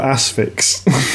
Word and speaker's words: Asphyx: I think it's like asphyxiation, surely.